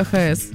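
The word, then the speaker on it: VHS: Ладно тебе, что такое ВХС? – ВХС-кассета. – ВХС, если правильно говорить.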